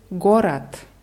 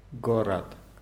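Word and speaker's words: Gorat (город): Wird mit zwei O's geschrieben. Gorod. Die Betonung ist auf dem ersten, also sprechen wir im ersten Fall O und im zweiten Mal wieder dieses reduziertes A. Gorat. – Gorat.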